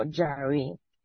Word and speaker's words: Yahvé: Era el tetragramatón sagrado, que sería YHWH, que se pronunciaría como Yahvé.